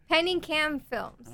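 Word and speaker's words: Pinikam Films: Penningcam Films. – Okay. Penningcam Films. – Yes. – P-I-N-I-K-A-M.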